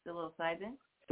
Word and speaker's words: psilocybin: We're talking about psilocybin.